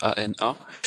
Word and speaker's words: ANA: ANA en fait, ça se prononce ANA.